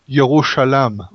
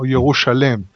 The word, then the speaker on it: Yerushalayim: Because it's pronounced Yerushalam or Yerushalem.